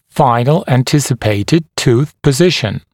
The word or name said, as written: final anticipated tooth position